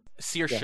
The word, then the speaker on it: Saoirse: Saoirse, yes.